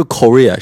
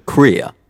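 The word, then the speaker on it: Corea: 结果 chick 特别严肃说，他说不是，他说不是 Korea, 是 Korea。我可能一直以来我念他名字的时候发音也都是错了，老是觉得是 chick Korea, chick Korea, 但是其实是 chick Korea, 就是这样连下来的。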